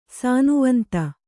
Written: ಸಾನುವಂತ

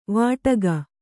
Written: ವಟಕ

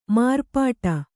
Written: ಮಾರ್ಪಾಟು

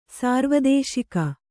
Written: ಸಾರ್ವದೇಶಿಕ

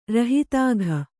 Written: ರೋಹಿತಕ

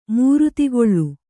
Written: ಮೂರ್ತಿಗೊಳ್

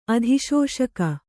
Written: ಅಧಿಶೋಷಕ

